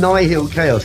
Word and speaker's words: Nihil: I'm calling them Nihil Chaos because it's spelled N-I-H-I-L and then K-A-O-S. And I reckon it's Nihil as in short for nihilistic. Mm. Rather than nihil. Nihil Chaos.